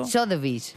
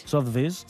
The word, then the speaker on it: Sotheby's: Sotheby's. Sotheby's.